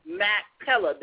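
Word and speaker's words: Machpelah: If they have access to Maxella, and for everybody who's interested, it is spelled M-A-C-H-P-E-L-A, Maxella.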